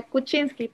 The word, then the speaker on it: Kuczynski: the Kuczynski presidency? – Yeah, Kuczynski, Pedro Pablo Kuczynski.